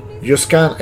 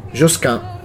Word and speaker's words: Josquin: Josquin è la pronuncia latina Josquin è la pronuncia francese.